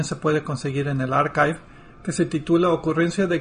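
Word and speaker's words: arXiv: Archive, con X. Sí. – Con X, sí. La pronunciación que he escuchado y que todo el mundo usa, incluyendo los que teníamos duda, es Archive.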